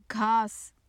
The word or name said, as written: ghas